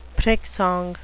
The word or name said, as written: prick-song